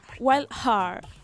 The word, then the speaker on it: Wild Hare: Me encantó esa pronunciación, a ver cómo es Wild Hare. – Wild Hare.